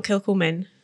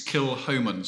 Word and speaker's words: Kilchoman: So first of all, is it Kilhoman, not Kilcoman? – It's Kilhoman, so Kilhoman. Silent C, yes.